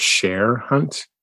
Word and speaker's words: Cher Hunt: You pronounced the main character's name Chair Hunt instead of Cher Hunt. – Yeah. – And it's short for Cherokee, so that made perfect sense.